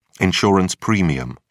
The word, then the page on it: insurance premium: British English